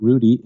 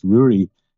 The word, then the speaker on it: Ruaidhri: So it's not Rudy, it's Rury is my name. So and it rhymes with the brewery, which is also very familiar with people who are dealing with Irish people in general.